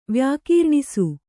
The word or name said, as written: ವ್ಯಾಕೀರ್ಣಿಸು